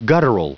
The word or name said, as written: guttural